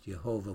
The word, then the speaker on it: Yahweh: Now, the Hebrew word that most often has the word Lord associated with it is the word Yahweh, also by some pronounced Jehovah, which is not as an accurate pronunciation as they know these days, as Yahweh.